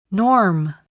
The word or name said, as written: norm